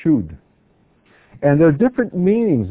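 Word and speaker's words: shud (שוד): It's the word shud. Shin, vav, dalet.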